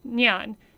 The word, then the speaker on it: Nian: One of the most popular is about the mythical beast Nian. It says the pronunciation is N-Y-E-N.